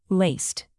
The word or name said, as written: laced